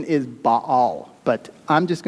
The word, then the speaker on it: Baal: Now, just so you know up front, the correct pronunciation of this god's name is Baal. Okay, but as I was going through this message and preparing it, it felt awkward to keep saying it that way, Baal.